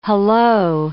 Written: hullo

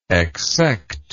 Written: exsection

